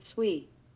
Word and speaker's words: SWE: This acronym, SWE, is commonly pronounced SWE.